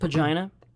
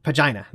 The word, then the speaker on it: Pegana: I believe it's pronounced Pagina. – Pagina. There you go. – Yes. – Which features a Pagania, I think.